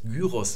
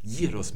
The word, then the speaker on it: Gyros: Selbst Gyros sagen wir, das ist die altgriechische Aussprache, während man auf Neugriechisch Gyros mit J und I sprechen würde.